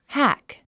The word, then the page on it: hack: hǽk; ハク